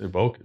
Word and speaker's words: Evoque: Am I saying it right? It's Evoque. It's not Evoque.